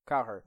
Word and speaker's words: Cowherd: How do you say his name? Cowherd? – Cowherd.